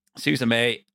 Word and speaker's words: Suzume: I think it centers around a young girl called Suzume. That's the best way I'm going to pronounce it. – Susame? Susame? – No, it's because the little little creature in it calls her. It goes, Suzume? Suzume? – All right.